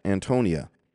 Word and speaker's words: Antonia: I don't know if it's Castle Antonio to Antonia. Let me look at something real quick. Yeah, Antonia. I thought I was pronouncing it right.